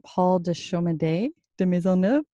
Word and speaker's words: Paul de Chomedey de Maisonneuve: The magazine takes its name from Paul de Chaumedey de Maisonneuve, which I'm not sure if I'm saying that right. – Yes.